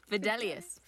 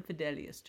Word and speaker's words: Fidelius: Fidelius. – I'm sorry, how did I say it? – Fidelius. – Fidelius, sorry.